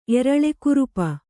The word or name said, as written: ಎರಳೆಕುರುಪ